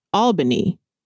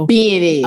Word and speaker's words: Albany: I pronounced that Albany when I first saw mm-hmm. it. I was like, oh, okay, you're from Albany, Georgia. And he was like, that's not where I'm from. Mm-hmm. He was mm-hmm. like, I'm from Albany is how. – Albany. – Okay, mm-hmm. that's how he told me to say it.